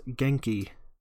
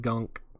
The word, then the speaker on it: Genki: By the way, thank you to Miles Morales Spider-Man for finally telling me how to pronounce Genki. Which I had no idea when reading Miles Morales comics. I thought it was like Gunk or Gunka or something.